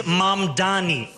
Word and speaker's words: Mamdani: The name is Mamdani. M-A-M-D-A-N-I. You should learn how to say it.